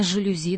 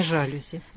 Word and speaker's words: жалюзи: Ну, конечно, во французском с ударением все просто. Там ударение всегда падает на последний слог. И поэтому правильно «жалюзи» достаточно помнить, что слово это из французского, чтобы правильно поставить ударение. – А не «жалюзи».